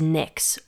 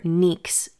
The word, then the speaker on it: Nyx: And sibling to Erebos is Nyx, or Nyx as it's pronounced in Greek, the primordial goddess of night, also one of the very first gods.